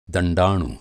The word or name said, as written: दण्डाणु